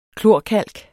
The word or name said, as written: KLORKALK